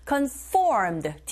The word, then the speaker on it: conformed: 윗니로 아랫입술 살짝 긁는 듯한 느낌으로 해주시고 conformed 뒷부분에 강세 있습니다.